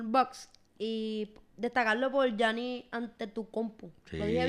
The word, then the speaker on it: Antetokounmpo: Hay una película en Disney Plus de la historia de Giannis Antetokounmpo.